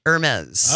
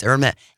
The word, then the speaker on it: Hermès: Because how you pronounce it? – Hermes. – All right. – It's very snooty. – I thought it was Hermes. – No, it's Hermes. Hermes.